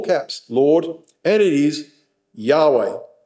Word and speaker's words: Yahweh: And in the Hebrew, that is the word pronounced Yahweh.